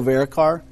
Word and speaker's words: Varadkar: Leo Varadkar.